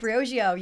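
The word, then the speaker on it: Briogeo: The Brio Digio. I hope I'm saying that right. Brio Digio. It's such a great product. – Brio Digio, yes.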